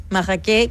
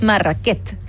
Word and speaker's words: Marraquet: Es más, ya se sabe que probablemente llegó a Valparaíso a través de dos hermanos franceses llamados Terron Marraquet, Marraquet que termina con T, que no se pronuncia, pero en el fondo es Marraquet. Y por eso la marraqueta.